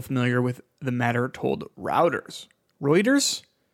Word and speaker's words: Reuters: Reuters? Reuters, yeah. – I call it Routers. Reuters, wonderful name here.